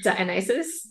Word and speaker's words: Dionysus: D I can't Dionys why can't I say it right now? What is wrong with me? – Dionysus. – Yes.